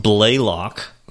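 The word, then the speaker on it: Blalock: Uh, it is pronounced Blaylock. – So Blaylock. even there, even though there isn't a Y, there should be.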